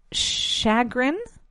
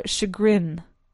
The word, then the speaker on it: chagrin: I'm actually going to look it up again because there are two ways of pronouncing it. It's chagrin or chagrin.